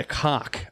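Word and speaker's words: kok: That round ball is called a cock, a K-O-K it's spelled.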